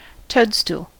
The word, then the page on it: toadstool: American English